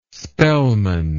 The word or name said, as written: spellman